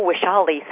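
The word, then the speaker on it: Vaishali: And I know Washali sounds like it's spelled with a W, but it's a Sanskrit name. And in Sanskrit, it's spelled with a V, but according to English rules, you pronounce it like a W.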